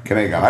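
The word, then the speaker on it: Kanegon: Yeah, that's Kangion. – Is that Kangion? – Is digital Kangion. – Okay. That's the one. – Or Kanagon. Sorry, Kanagon. I've been pronouncing it wrong for like 10 years.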